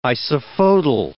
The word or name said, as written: isophotal